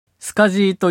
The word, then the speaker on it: SCSI: 当時周辺機器をつなぐマックとハードディスク、外付けのハードディスクだったりスキャナーだったり MO、光磁気ディスク、ドライブだったり CD-ROM ドライブだったりをスカジーという規格でつないでいたんですね。スカジーケーブルというケーブルで SCSI と書いてスカジーと読むんですね。